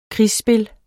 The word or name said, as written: krigsspil